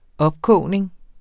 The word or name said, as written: opkogning